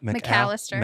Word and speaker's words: McAllister: McAllister. – McAllister. – Yeah. – Like the deli. McAllister's yes. – Deli.